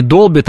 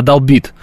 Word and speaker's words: долбит: Вот мне подсказывают, что мне подсказывает, что правильно не долбит, а долбит. Я посмотрел, в русском словесном ударении нет, но вообще по логике, если звонит, да, то, наверное, долбит правильно.